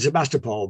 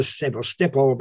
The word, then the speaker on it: Sevastopol: Notice, by the way, I'm giving it the correct Ukrainian pronunciation there. Now, I would have said Sevastopol, but it's now Sevastopol because I've been there. – I think the trick is with Russian pronunciation, if you're English, is always put the emphasis on the syllable that feels uncomfortable.